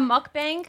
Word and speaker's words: mukbang: Isn't that what it's called? A mukbang? – Yeah. – Am I saying that right? – Mm-hmm.